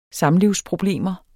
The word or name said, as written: samlivsproblemer